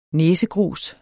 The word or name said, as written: næsegrus